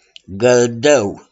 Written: GĂLDĂU

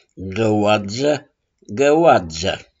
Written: GĂOADZĂ

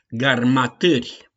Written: GARMATÂRI

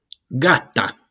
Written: GATA

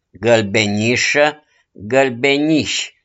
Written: GĂLBENIȘĂ

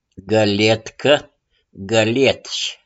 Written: GĂLIETCĂ

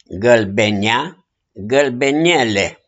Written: GĂLBENEA

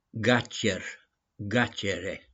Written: GACER

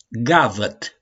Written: GAVĂT

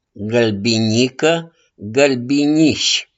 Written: GĂLBINICĂ

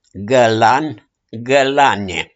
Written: GĂLAN

